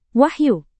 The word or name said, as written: wahyu